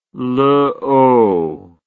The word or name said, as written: luo